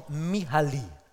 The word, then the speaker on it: Mihaly: Das ist sein Vorname, der wird ausgesprochen Mihaly. Also sprechen wir mal zusammen Mihaly. Okay, macht ihr gut.